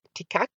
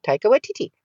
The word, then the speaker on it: Taika Waititi: Because when I was saying Taika, people would be like, Tikak? How do you pronounce it? I was like, Taika Waititi, Taika Waititi.